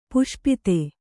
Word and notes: ಪಾಶುಪತ: pāśupata

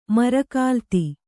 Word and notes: ಮರಕಾಲ್ತಿ: kannada

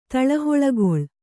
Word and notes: ತಳಹೊಳಗೊಳ್: taḷahoḷagoḷ

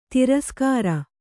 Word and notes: ತಿರಸ್ಕರ: tiraskara

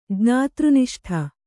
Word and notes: ಜ್ಞಾತೃನಿಷ್ಠ: jñātř niṣṭha

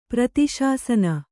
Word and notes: ಪ್ರತಿಶಾಸನ: prati śasana

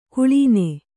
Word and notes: ಕಳನಿ: kaḷani

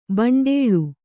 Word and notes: ಭಂಡೀಲ: bhaṇḍīla

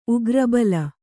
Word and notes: ಉಗ್ರಬಲ: kannada

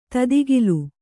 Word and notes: ತದುಕಿಲು: tadukilu; Kannada

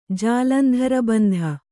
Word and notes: ಜಾಲಂಧರಬಂಧ: Kannada

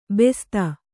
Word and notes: ಬಸ್ತಿ: Kannada

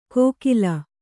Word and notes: ಕೋಕಿಲ: kōkila

